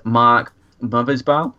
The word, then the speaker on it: Mothersbaugh: It's actually a composer called, and correct me on my pronunciation here, because I'm awful, but I'm going to go with it, Mark Mothersbaugh. Is that we're going for? – I believe that's how you pronounce it, yes.